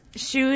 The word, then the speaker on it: Schuh: Hsu-less, Hsu is actually our last name. – Okay. – It's spelled S-C-H-U-H, not S-H-O-E.